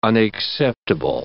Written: unacceptable

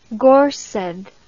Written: gorsedd

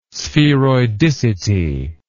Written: spheroidicity

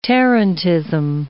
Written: tarantism

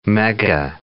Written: mega

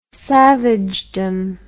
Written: savagedom

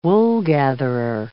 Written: woolgatherer

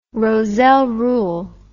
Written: Rozelle rule

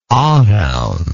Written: Arawn

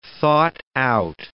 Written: thought-out

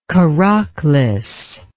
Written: Karaklis

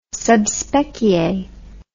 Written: sub specie

